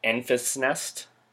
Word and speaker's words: Enfys Nest: It's uh, is it uh, Enfys Nest? – Uh, that sounds correct.